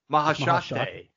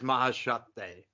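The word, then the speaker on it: Maheshate: Also, we missed how to say it too. We've been blowing it. Mahashate. – And he just goes by that. He doesn't go by the highest air. – Yeah, it's just Mahashate.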